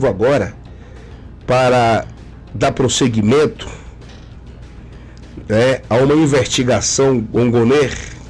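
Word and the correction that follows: Gonet: É Gonner o nome dele, é? Gournet. É que se pronuncia como Gournet.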